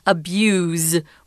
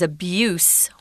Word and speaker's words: abuse: It's spelled the same, but it's pronounced abuse with a Z. But the noun is abuse with an, an S sound.